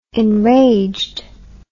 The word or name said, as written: enraged